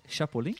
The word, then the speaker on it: Chapolin: É, como se fala, como se pronuncia em espanhol? É, Chapolin? – Chapolin.